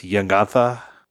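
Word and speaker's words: Jegantha: Gigantha. – Should we just call it Jenga from now on? – We like the soft J sometimes here on the pod. It's just Gigantha.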